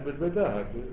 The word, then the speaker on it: بالبداهة: وما ثبت منه أي من العلم الثابت بالعقل بالبديهية. – لا بالبداهة. آيات تلفظ ولا تكتب ولا تلفظ. – بالبداهة. – أو قسم من يقول بديهة. يعني. بديهية يعني سماها. – نعم. – بس هي بداهة.